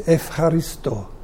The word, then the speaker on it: Ευχαριστώ: But at least it's something you're trying to talk in their own, just EU it is, but they pronounce it with an F, Ευχαριστώ.